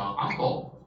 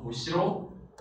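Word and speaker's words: 後: OK，那么这个后呢，要注意一下，它有几个读法，它可以念成阿后啊，对吧？表示之后的，还有的啊，还可以念成无西喽啊，表示后面的，对吧？还可以念成什么呢？哎，诺期也是啊，稍后的意思啊，稍后诺西后头，对吧？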